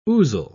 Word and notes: ousel: British English

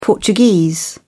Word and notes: portuguese: British English

